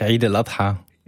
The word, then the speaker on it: Eid el-Adha: Eid el-Adha. Habe ich es richtig ausgesprochen? – Richtig, Eid el-Adha.